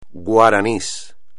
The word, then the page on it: guaraní: espagnol